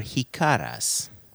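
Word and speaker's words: jícaras: These are hikaras. And I'm going to spell that for people. J-I-C-A-R-A-S.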